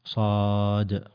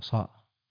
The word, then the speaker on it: صاد: وصاد في القرآن نقول باسمها صاد، والقرآن ولا نقول بصوتها صاء.